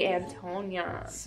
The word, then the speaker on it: Antonyan: We got here Suzy Antonyan.